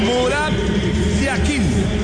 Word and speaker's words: Murat Yakin: Uno es pronunciación suiza y la otra pronunciación turca. En el suizo se pronuncia así: Murat Yakin.